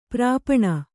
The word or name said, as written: ಪರ್ಪಾಣಿ